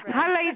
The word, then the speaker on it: challah: Challah is the bread that the Jewish eat. So you gotta say it the right way. It's a challah. – Challah. – Challah. – They can't pronounce ha.